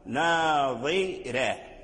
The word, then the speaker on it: ناظرة: ترقيق الراء يعني لمناسبة كسرة الضاء، ناظرة ناظرة تنطق بالضاد مكسورا تحافظ على تفخيمه حتى لا يرجع دالا لأن الفرق بينه وبين هكذا، ثم ترقق الراء لأجل كسرة الضاد